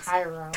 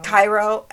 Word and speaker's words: Cairo: But during the trial, it was actually revealed that as a child living in Cairo, I'm so sorry, in Egypt, I'm sorry if I pronounce Cairo. – Cairo, actually, yeah, that sounds right, it is.